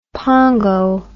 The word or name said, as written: pongo